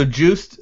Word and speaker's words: Joost: So Joost and his, I think that's how you pronounce his name, his real name, his nickname is Yoast, but his entire team, the Yoast team, they were wearing sweatshirts that said Yoast team.